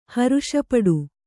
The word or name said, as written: ಹರ್ಷಪಡು